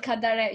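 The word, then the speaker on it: Kadare: Ismail Kadari.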